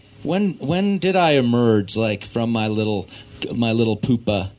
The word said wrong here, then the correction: pupa: Pupae? – Oh, pupae, pupa.